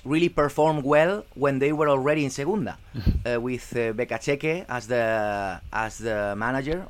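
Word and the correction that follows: Beccacece: Or is Bekaseche? I don't really know. – Bekacheche. – The way to spell it. Bekacheche, okay.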